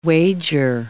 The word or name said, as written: wager